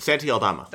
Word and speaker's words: Aldama: Santi Aldama. – That's what I was going to say. I was going to pronounce it like I would like a Spanish name.